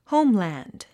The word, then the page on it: homeland: hóumlæ̀nd; ホウムランド